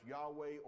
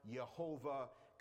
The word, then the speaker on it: Yahweh: YHWH, which we pronounce Yahweh or Jehovah.